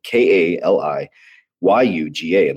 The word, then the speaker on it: Kali Yuga: But there's a really cool post that he put out there, and it's called "We Are in the Kali Yajuga," if I say that right, Kali Yuga.